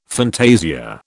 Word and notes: fantasia: British English

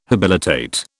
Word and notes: habilitate: British English

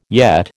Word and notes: yet: American English

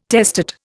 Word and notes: testate: Indian

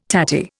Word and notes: tatty: Indian

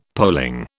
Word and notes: polling: British English

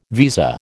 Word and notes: visa: American English

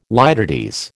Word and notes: litotes: American English

